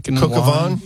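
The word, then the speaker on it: coq au vin: And I don't know how to say this. Is it Coque au Vin Day, the French dish? – Oh, yeah, the chicken and Coque au Vin. Yeah. That's a classic.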